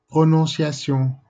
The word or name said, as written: Eihel